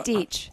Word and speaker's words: Deech: Deech. – Deech. – Oh, rhymes with beach.